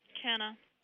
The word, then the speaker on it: Kenna: Kenna. – And how do you spell it? – K-E-N-N-A.